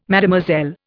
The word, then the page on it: Mademoiselle: Indian